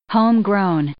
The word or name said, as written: homegrown